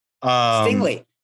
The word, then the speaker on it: Stingley: Am I saying that wrong? – Stingley. It's Derek Stingley.